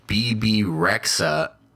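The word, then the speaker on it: Bebe Rexha: Before the end of the show, where you find out that BB Rexha is joining.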